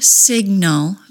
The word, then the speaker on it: signal: Because both the G sound and the N sound are voiced, the release of the G ends up being pronounced as the vowel sound, uh. When a vowel sound is added to a word, so is a syllable. The correct pronunciation of signal is two syllables. The incorrect pronunciation, signal, has three syllables.